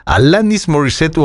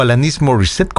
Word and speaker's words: Alanis Morissette: Alanis Morissette, o Alanis Morissette, como lo quieran pronunciar, estaba ganando varios premios en los Juno Awards, que era la 25ª edición de estos premios.